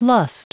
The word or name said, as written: lust